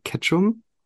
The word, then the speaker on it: Ketchum: Du arbeitest ja bei Ketchum.